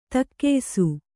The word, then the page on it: ತಕ್ಕೆಯ್ಸು: takkeysu